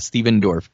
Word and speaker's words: Stephen Dorff: Yeah, Stephen Dorff.